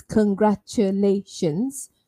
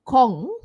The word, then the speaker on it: congratulations: So it's congratulations. That's five syllables. So it's not Kong, even though it's spelled C-O-N-G, like you think of Kong, you think of King Kong, for example, Kong like that.